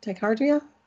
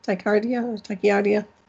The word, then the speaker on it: tachycardia: He's asking, ask Dr. Johnny if he has dealt with people that have SVT, supraventricular tachycardia. Excuse me, tachycardia. I said that wrong.